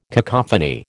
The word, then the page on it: cacophony: American English